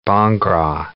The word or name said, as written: bhangra